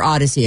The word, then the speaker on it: Audacy: All this week, all you have to do is log into your Odyssey account. – Odyssey. – If you don't have the app, it's free. It's spelled A-U-D-A-C-Y, like think audio. Audacious. Odyssey.